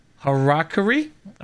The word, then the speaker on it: Harakiri: Harakiri, am I saying that right? Hacker, let you say that one. I fit 45. – And you got it.